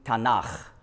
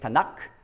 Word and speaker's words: Tanach: Und dann gibt es eben Tanach. Warum nicht Tanak? Ganz einfach, wenn man Hebräisch lernt, muss man wissen, dass gewisse Buchstaben, wenn ein Vokal vorausgeht, die werden die weich.